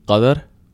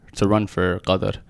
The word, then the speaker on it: Qatar: So the Arab pronunciation would be Qatar. – Okay. – But Qatar is probably the closest you can get.